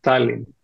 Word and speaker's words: Tallinn: Ο λόγος είναι ότι όλες οι λέξεις στα εσθονικά τονίζονται στην πρώτη συλλαβή. Είναι ακριβώς ό,τι βλέπεις, διαβάζεις από τα γερμανικά. Αλλά ο τονισμός είναι πάντα στην πρώτη συλλαβή, οπότε το σωστό είναι Tallinn.